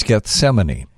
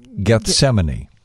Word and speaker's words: Gethsemane: It's Gethsemane. – Come again? – Gethsemane. – Gethsemane? Look at you showing off. You're just saying it. – Gethsemane. – Gethsemane. – G-E-T-H.